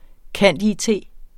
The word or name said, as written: cand.it.